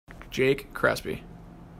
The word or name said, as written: Crespi